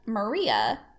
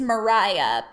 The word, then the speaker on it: Maria: and young Maria. Oh, no, it's Mariah, but it's spelt Maria.